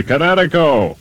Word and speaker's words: Kinetico: Everybody knows that Kinetico is spelled K-I-N-E-T-I-C-O. – I thought the instructor said Connecticut. Oh, on the East Coast. – Son, you should have remembered kinetic as in kinetic energy with an O on the end. Kinetico. – Kinetico. Spell it K-I-N-E-T-I-C-O.